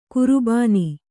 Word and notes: ಕರಬೋನ: karabōna

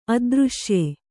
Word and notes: ಅದೃಶ್ಯ: adřśya

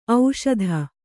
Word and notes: ಓಷಧಿ: ōṣadhi

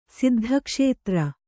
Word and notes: ಸಿದ್ಧಕ್ಷೇತ್ರ: siddha kṣētra

